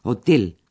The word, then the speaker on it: Hotel: Y le pide al taxista que lo lleve a su hotel, que también se dice hotel en alemán. Si bien en este caso la pronunciación es algo distinta. Presten atención. Hotel en español. Hotel en alemán.